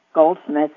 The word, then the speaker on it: Goldsmith: Hello, my name is Connie Goldsmith. Goldsmith is pronounced just like it's spelled, Gold and Smith.